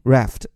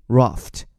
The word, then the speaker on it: raft: 发音区别，如果是美音 raft，如果是英音 raft，这次本来指的什么呢？